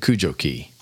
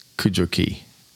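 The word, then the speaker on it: Cudjoe: It's located to the left on Cujo Key. Big arguments on how to pronounce that thing. C-U-D-J-O-E. I'd say Cujo. Some people say Cujo.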